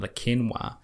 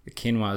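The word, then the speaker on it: Le Quesnoy: And if you're curious about it, it's called the New Zealand Memorial Museum in Lakenwa. Lakenwa is spelt differently from it sounds. Obviously, it's French, but it's Lequesnoy, I believe.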